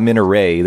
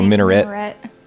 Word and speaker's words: minaret: It's either minaret. I guess it's minaret, right? – Okay. – It's not minaret. They wouldn't say it like a French word. So minaret.